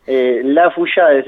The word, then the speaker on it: Laffeuillade: Lafeuchiade se pronuncia, pero tiene todo el alfabeto ahí metido.